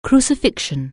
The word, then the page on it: crucifixion: anglais